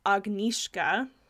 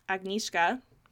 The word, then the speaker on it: Agnieszka: The way her name is pronounced is Agnieszka. – Agnieszka? – Agnieszka. – Okay. – So we can try to say that. It's Polish.